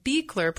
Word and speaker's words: Beachler: Hannah, it's Beachler pronounced, but it's like beach, going to the beach with the sand, L-E-R.